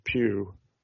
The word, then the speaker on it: Pugh: I said to him that they had this Florence Pugh, and I'm hoping I'm saying her name correctly. – Who's a great? – What's that? – That's how you say it.